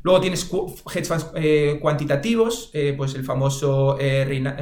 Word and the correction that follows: Renaissance: Reina, joder, siempre lo pronuncio mal, Renaissance,